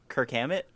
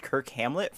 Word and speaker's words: Kirk Hammett: I didn't know how to say his last name, and same with the guitarist there, fucking Kirk Hammett. I thought his name was pronounced Kirk Hamlet for about 8 years, and I said it out loud once, and somebody was like, "Kirk Hamlet?